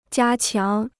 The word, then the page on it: 加强: jiā qiáng